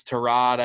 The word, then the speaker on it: Tyrod: He's like, "Hey, uh, Baker called you Tyrod. Is that like your name, or did Baker mispronounce it?" And he's like, "No, like my name's Tarad. I just never corrected anybody."